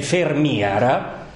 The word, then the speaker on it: Vermeer: Pensate nei Paesi Bassi, il famoso, uno dei pittori più amati e più famosi del mondo, dopo Caravaggio forse Michelangelo, è Vermeer, che in realtà in fiammingo si legge Vermeer.